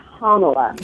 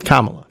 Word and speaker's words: Kamala: Kamala, and how do you pronounce it? Because I hear it different times all the time, so I want to get this right. Kamala? – I believe she says her name is Kamala. – Kamala, okay.